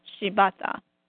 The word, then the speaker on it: Shibata: Shibata.